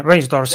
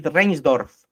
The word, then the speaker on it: Reinsdorf: Creo que lo he pronunciado bien. El dueño. – Sí, Reinsdorf, sí. – Jerry Reinsdorf es un conocidísimo, ya desde la época de Jordan, de muy tacañón.